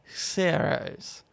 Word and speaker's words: Xherios: Okay, how would you pronounce this then? X-H-E-R-I-O-S. Zeros. Zeros.